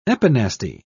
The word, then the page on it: epinasty: British English